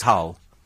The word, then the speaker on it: Gaal: And somebody called Louis van Gaal. Is it? Sorry? Louis van Gaal. Oh, right. In the frame for the top job. It's spelt Gaal, is it? But it's Hull.